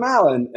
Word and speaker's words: Allan: And I was like, oh, I'm Alan.